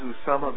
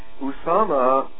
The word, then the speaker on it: Usama: One is Osama bin Laden, and the head of Al-Qaeda. Because if you phonetically spell in Roman letters, the correct phonetic spelling is USAMA, not OSAMA.